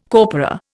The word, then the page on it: corpora: Indian